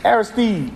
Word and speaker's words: Aristide: Aristide. Like the Aristide brothers at Northwestern.